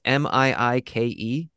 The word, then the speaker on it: Miike: If nobody's ever seen the director, and I might butcher his name because I don't know how to pronounce Japanese, Takashi Miike. It's Miike.